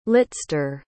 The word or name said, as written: litster